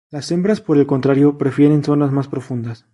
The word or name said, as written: contrario